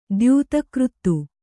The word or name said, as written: ದ್ಯೂತಕೃತ್ತು